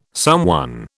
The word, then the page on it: someone: British English